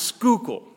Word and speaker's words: Schuylkill: It's pronounced Schuylkill, like school or scuba or whatever, but Schuylkill, scooter, but Schuylkill. And I said, but there's a Y in it.